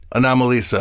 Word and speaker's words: Anomalisa: How do you say it? – Anomalisa. – Anomalisa. – Like Anomaly and Lisa.